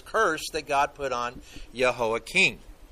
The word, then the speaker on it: Jehoiakim: We're going to find out, first off, Jehoiakim, and remember, in Hebrew, J's are pronounced with a Y sound, so you'll see it in your Bible as J, but it's Jehoiakim.